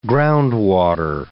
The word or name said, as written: groundwater